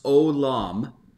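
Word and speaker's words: Olam (עולם): The word is pronounced Olam.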